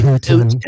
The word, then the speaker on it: Putin: But on the storyline, Putin. – Putin. You have to say it like this. You have to say Putin.